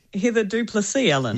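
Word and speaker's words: Heather du Plessis-Allen: Heather Du Plessis Allen.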